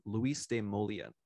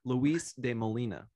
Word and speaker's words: Luis de Molina: It finds its roots in the Spanish theologian from the 16th century named Luis de Molina. I'm sorry, I mispronounced his name. Luis de Molina.